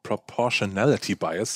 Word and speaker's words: Proportionality Bias: Wenn wir schon bei Ereignissen sind, äh, dann sind wir auch eigentlich direkt in der Psychologie, weil es gibt sowas wie den Proportional Proportionality Bias, so jetzt habe ich das Wort richtig ausgesprochen.